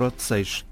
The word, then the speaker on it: Odeceixe: E então, o jornal tem à frente da palavra, em português, o modo como o inglês deve pronunciar Odeceixe, que é Odeixaxe.